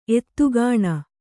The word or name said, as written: ಎತ್ತುಗಾಣ